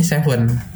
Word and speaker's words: Se7en: Ditulisnya tuh S E 7 E N. Jadi tetap bacanya Seven.